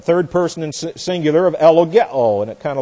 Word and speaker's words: ellogeo: This is E-L-L-O-G-E-O, long O on the end. Elogeo.